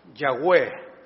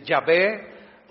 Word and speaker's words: Yahweh: El más acercado, dicen los eruditos, es Yahweh, ¿verdad? Pero bueno, así para poderse pronunciar, algunos dicen Yahvé, otros dicen Jehová, pero es nada más añadiendo unas vocales para que tenga pronunciación.